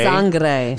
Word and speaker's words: Sangre: Dead batteries, crooked cobras, and I never say this right, mala sangre. – Sangre. – Sangre. I got it.